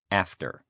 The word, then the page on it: after: male